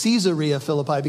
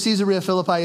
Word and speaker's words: Caesarea Philippi: Now, when Jesus came into the district of Caesarea Philippi, you could pronounce this Caesarea Philippi because it was named after the Caesar, the Roman emperor.